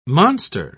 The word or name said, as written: monster